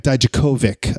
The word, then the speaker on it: Dijakovic: Also, I want to send a shout out to uh, Dominic Dijakovic or Dominic Dijakovic, I guess is the way you pronounce it, uh, from WWE NXT, the former Donovan Dijak, past guest here on the Wrestling Podcast About Nothing.